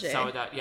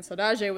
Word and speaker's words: saudade: Saudade. – Saudade.